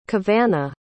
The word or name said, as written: cavana